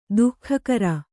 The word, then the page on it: ದಗಾಕೋರ: dagākōra